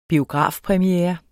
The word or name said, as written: biografpremiere